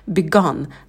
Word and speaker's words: begun: Первый – begin. И, краткий и. Потом began, звук лягушка, когда мы улыбаемся, чуть отстраняем вниз и быстро ставим на место. Begun, краткий а, рот минимально открывается.